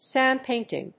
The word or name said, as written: sand painting